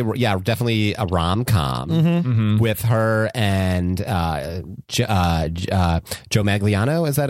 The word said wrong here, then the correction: Manganiello: Am I saying his name right? – Joe Manganiello. – Joe Manganiello, yeah.